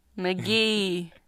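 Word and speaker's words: Mk.gee: This is pick by MKG. – McGee. Hey, you got the pronunciation.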